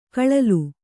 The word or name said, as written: ಗಳಲೆ